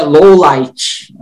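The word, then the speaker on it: Lawliet: A pronúncia correta do nome é Lowlight. Ah, tá.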